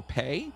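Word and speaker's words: pā: To protect themselves from being attacked by others, Maori would construct a, it says long A, so pay.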